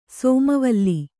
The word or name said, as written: ಸೋಮವಲ್ಲಿ